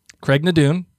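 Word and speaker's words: Craigh na Dun: Craig Nadun.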